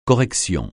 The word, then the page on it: correction: francais